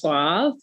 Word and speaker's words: Suave: It's pronounced Suave. Suave. And Suave, although I did like your pronunciation.